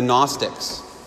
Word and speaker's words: Gnostics: One group I'll tell you about is the Gnostics. If you've ever heard of the Gnostics, it's spelled with a G at the front.